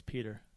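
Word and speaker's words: Pyotr: I would have never guessed Peter. – What it is? What it is? The O is silent and the E is non-existent. – Right. Yeah, and the Y sounds like an E.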